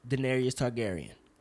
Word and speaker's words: Daenerys Targaryen: Daenerys Targaryen.